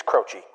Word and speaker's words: Croce: The proper way to say his name is Croce.